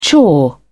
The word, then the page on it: chore: British English